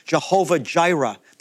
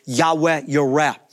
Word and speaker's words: Jehovah Jireh: And by the way, that's where we get the phrase Jehovah Jireh, the Lord our provider. It's actually pronounced something closer to Yahweh Yireh.